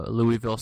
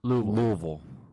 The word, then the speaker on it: Louisville: You're saying it wrong. – Sorry, Louisville. – Louisville. – Louisville. – They will correct you.